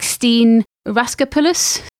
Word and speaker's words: Steen Raskopoulos: She has a semi-serious boyfriend called Evan, played by, and I'm going to get this name wrong, I think it's something like Steen Raskopoulos. – That sounds about right.